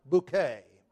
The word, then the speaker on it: Bucket: and she has decided that the name Bucket really should be pronounced Bouquet.